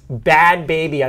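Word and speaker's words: Bhad Bhabie: So we're talking about Behad Behabi. – Bill means bad baby, I believe is the prevailing pronunciation, a.k.a.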